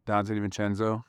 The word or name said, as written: Donte DiVincenzo